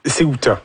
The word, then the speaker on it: Ceuta: Et puis là, j'essaie de prononcer cette ville-là. Ceuta? – Ceuta. – Ceuta. C'est ça que je voulais dire.